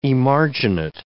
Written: emarginate

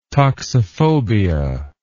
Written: toxiphobia

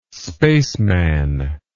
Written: spaceman

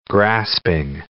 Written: grasping

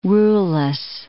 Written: ruleless

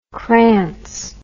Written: krantz

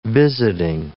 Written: visiting

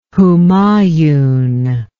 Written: Humayun

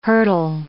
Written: hurtle

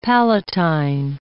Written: palatine

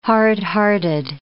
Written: hard-hearted

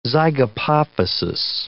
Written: zygapophysis

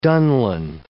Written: dunlin

